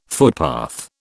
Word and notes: footpath: British English

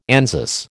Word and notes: Anzus: American English

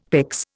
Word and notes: pyx: Indian

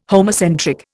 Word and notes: homocentric: Indian

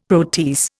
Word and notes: protease: Indian

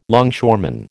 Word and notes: longshoreman: American English

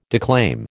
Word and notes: declaim: American English

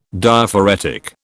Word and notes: Diaphoretic: British English

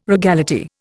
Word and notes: regality: Indian

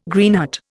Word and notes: Greenheart: Indian

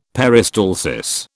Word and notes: peristalsis: British English

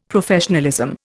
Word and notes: Professionalism: Indian

